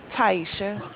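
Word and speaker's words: Tyesha: Taisha. – Can you spell it for me? – T as in Tom, Y-E-S-H-A.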